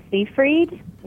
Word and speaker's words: Seyfried: Amanda Seyfried, I think that's how you say her name.